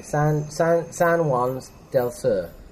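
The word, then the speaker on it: San Juan del Sur: So, from our position at the moment, which is up in the hill overlooking the I'm going to try the pronunciation here. We've not said that, have we? San Juan's del Sur. Yeah? Happy with that? – Nailed it.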